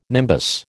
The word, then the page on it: nimbus: American English